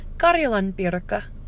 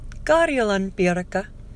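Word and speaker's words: Karjalan piirakka: Yeah, in Finnish they're called Karjalan pirka. Karjalan pirka. Karjalan pirka. I'm probably saying that wrong. I'm supposed to draw out the iraka. I speak too fast for a Finn. So, Karjalan pirka.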